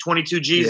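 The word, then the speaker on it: 22Gz: But when I commented on that, 22 G's, is that how you say it? 22 G's, yeah.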